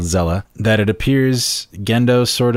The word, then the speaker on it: Seele: It's spelled SEAL, like S-E-E-L-E, but it's pronounced Zella. So... – Zella.